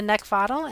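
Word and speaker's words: Neckvatal: And over in YouTube and LinkedIn, you can find me at Brenda Neckvottle, and my last name is spelled N-E-C-K, like the thing you want to choke, V as in Victor, A-T-A-L.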